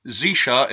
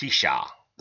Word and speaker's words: Xisha: The Paracel Islands, also known as Zisha in Chinese, and pretty Huang... sure that's pronounced Xisha. – Zisha. Xisha.